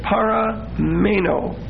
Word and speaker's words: parameno: The Greek word there, where it says, and continueth therein, is parameno. P-A-R-A-M-E-N-O. And that's a long O. Parameno. P-A-R-A-M-E-N-O.